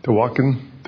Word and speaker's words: Tawakoni: Tawakoni. – Tawakoni.